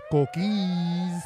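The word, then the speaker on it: coquí: Shout out to the queen of Los Coquis. Which make the most sound that I have ever heard in my life. A coqui, am I saying it right? – Yes.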